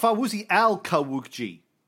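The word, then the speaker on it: Fawzi al-Qawuqji: I asked one of our Arab listeners to give me a phonetic pronunciation for that. Fawzi Kawukji. There you go. I think that's better. Kawukji. Oh, Fawzi al-Kawukji.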